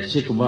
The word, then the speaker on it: شکوہ: ان دونوں نظموں میں، جیسا کہ ان کے نام سے ظاہر ہے، شکوہ اور جواب شکوہ، اردو میں ہم یہی تلفظ استعمال کرتے ہیں، عربی کا لفظ ہے، وہاں یہ شکوہ دعویٰ کی طرح، یعنی یہ سے لکھا جاتا ہے، شین پہ زبر.